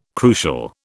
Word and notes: crucial: British English

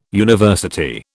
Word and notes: university: British English

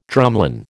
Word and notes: drumlin: American English

